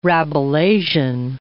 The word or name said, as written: Rabelaisian